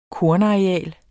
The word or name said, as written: kornareal